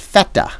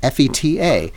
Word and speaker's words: feta: It's called feta, F E T A. Wait, how'd you just pronounce it? Feta, but Americans call it feta. Feta, like as if it's I do r- rhymes rhymes with fettuccine, and I cringe, but I would never.